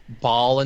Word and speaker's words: Baal: The pet is called Baal, B-A-A-L, B-A-A-apostrophe-L. – Yeah. Alex would kill us if we don't point out Baal in Diablo is actually pronounced Baal, yada, yada, Baal. Yada. This is Baal.